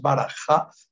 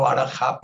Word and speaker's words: Baraj: Afbar, eh, eh, la, pronunciación eh, nos decía Neil Douglas en el último taller que nos hizo, no es Baraja, sino Baraj. Yo voy a seguir diciendo Barajá porque así aprendí, pero cada vez que me acuerde voy a decir Baraj porque parece que esa es la pronunciación correcta del arameo para referirse a esta carne,